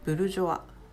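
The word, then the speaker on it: bourgeois: ブルジョア、ボジョルなんていうのも S は発音しません。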